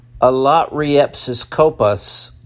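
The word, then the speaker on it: allotriepiskopos: This term, pronounced alotriepsis kopas, don't even try, is used only once in the Bible.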